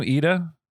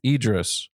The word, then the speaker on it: Idris: It's because, like, how do you say his name? Ida? – Idris.